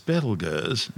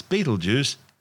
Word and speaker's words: Betelgeuse: Currently known as Betelgeuse, and commonly referred to by the public as Betelgeuse, don't say it three times, the names are both tortured mispronunciations of the original Arabic name Ibtal Yaza, meaning the hand of the big man being Orion the Hunter.